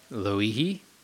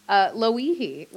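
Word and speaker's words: Lo'ihi (Lōʻihi): Lo'ihi. – There we go. I had to look it up too. Lo'ihi, which is the newest of the Hawaiian islands.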